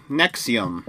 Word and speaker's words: NXIVM: Nexium. That's how you say it? – Yes. – Spelled NXIVM, but it's like Roman number letters, right? So the V is a U, right? – This is an interesting little cult.